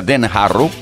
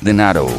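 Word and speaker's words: Den Harrow: Hay gente que lo pronuncia Den Harro, como yo alguna vez, y eso no es correcto. Es denaro, denaro, denaro, denaro.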